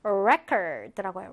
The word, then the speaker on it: record: 발음을 어떻게 하냐? record라고 해요. record. 자, 지금 강세가 아음절에 있죠?